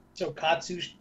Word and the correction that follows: tokusatsu: Tokusatsu. No, I'm just kidding. Tokusatsu.